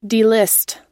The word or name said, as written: delist